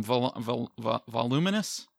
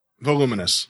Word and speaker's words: voluminous: Voluminous? – Voluminous. – Voluminous, yes. – Not voluptuous, but voluminous.